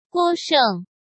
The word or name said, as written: Guo Sheng